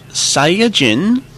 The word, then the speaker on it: Saiyajin: So, just to recap, we have Saiyajin versus Saiyan. – And just to note, since Japanese works on the system of more rather than straight-out syllables. It's actually pronounced in five beats, sai-ya-jin.